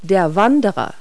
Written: hiker

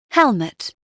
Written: helm

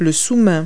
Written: deskmat

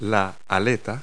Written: flipper

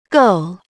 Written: goal

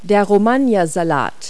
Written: romaine

